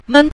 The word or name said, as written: men5